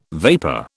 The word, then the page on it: vapor: British English